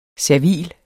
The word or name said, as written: servil